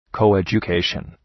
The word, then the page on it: coeducation: ,kəʋ,edʒʋ’keıʃən